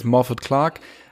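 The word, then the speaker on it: Morfydd Clark: Aber ich glaube halt, weil das halt im englischsprachigen äh, Raum das Walisisch dennoch nicht sehr verbreitet ist und die Leute sprechen es dann halt doch eher aus, wie es, wie man es im Englischen, im Britischen aussprechen würde. Äh, und da hat es ein bisschen geholpert. Also wir nennen sie jetzt Morford Clark.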